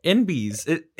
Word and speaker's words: enbys: NBs. It's spelled E-N-B-Y-S, but it literally is N-B, non-binary.